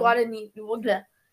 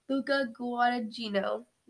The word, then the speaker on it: Guadagnino: Guadagnino. – Luca Guadagnino.